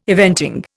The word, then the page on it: eventing: Indian